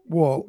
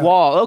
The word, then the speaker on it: Wuhl: Actually, it's pronounced... My family always pronounced it Wuhl, almost like the side of a building. – It is Wuhl, yeah. – Wuhl.